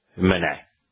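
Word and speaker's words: Meusnier: For those who are interested, you know, Google the name, Jean-Baptiste de Menet. Now, it's a French name, so it's not written as it is pronounced. The E is silent, the S is silent, and the R is silent. It's M-E-U-S-N-I-E-R.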